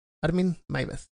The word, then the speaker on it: Meiwes: El caso del alemán Armin Meiwes, que se pronuncia uh, Armin Maives.